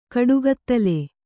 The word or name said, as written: ಕಾಡುಕಿತ್ತಲೆ